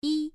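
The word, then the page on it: yi: tone 1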